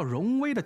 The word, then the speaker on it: 荣威: 就拿一款叫荣威的轿车来说，看似尊贵，但读音却像极了 wrong way，感觉像跑错了路，品牌效应可想而知。